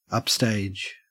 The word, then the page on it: upstage: Australian English